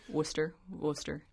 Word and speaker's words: Worcester: Is it, it's Worcester? Worcester.